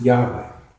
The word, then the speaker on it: Yahweh: I pronounce it Yahweh.